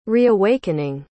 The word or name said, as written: reawakening